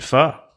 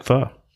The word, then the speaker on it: pho: The first one I think I kind of want to talk about is pheasant pho. And that ties into Corey's pheasant hunt and the fact that it's cold. In most places, not here, but cold in some. And I just like pho.